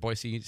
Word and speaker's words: Boise: Well, his bio is he's a political science professor at Boise State University. And by the way, that is how you say that.